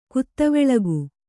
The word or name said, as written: ಕುತ್ತವೆಳಗು